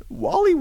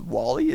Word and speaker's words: Wally: If you want to tweet me, if that's your preferred medium on Twitter, you could tweet me at Wally Wackerman. Now, let me explain how that's spelled. Wally is W-A-L-L-Y, simple enough. And then Wackerman, W-A-C-K-I-M-A-N.